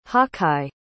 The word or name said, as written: hawkeye